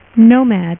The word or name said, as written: nomad